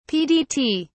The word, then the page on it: pdt: British English